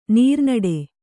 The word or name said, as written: ನೀರ್ನಡೆ